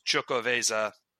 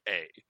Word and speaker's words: Xocoveza: It out there, and it's spelled with an X, it's Chocoveza, Xocoveza.